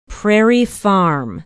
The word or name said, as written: prairie farm